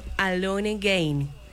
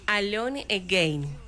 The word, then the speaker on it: Alone Again: Es una adaptación, Sandrito, al español del éxito internacional llamado Alone Again. – Perfecta la pronunciación. – Por favor. – ¿Eso es inglés? – Sí, señor. – ¿Cómo es? – Alone Again.